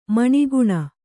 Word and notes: ಮನಗಾಣ್: managāṇ

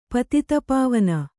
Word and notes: ಪತಿತಪಾವನ: patita pāvana

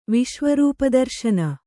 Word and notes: ವಿಶ್ವರೂಪದರ್ಶನ: viśva rūpa darśana

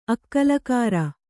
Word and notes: ಆಕಲಕರೆ: ākalakare

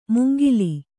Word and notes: ಮುಂಗಿಲಿ: mungili

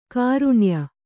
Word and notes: ಘ್ರಾಣೀಯ: kannada

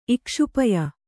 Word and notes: ಇಕ್ಷುಪಯ: Kannada

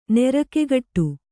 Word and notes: ನಿರಿಗೆಗಟ್ಟು: nirigegaṭṭu

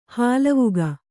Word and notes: ಹಾಲ್ವಕ್ಕಿ: hālvakki

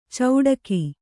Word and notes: ಚೌಟಗಿ: cauṭagi

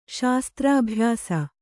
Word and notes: ಶಸ್ತ್ರಾಭ್ಯಾಸ: śastrābhyāsa

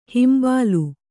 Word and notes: ಹಂಬಲು: kannada

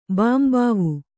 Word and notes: ಬಾಂಬಾವು: bāmbāvu

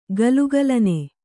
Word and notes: ಘಲಘಲನೆ: Kannada

